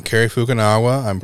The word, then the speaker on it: Fukunaga: Cary Fukunaga, probably saying that wrong. – I think that's right.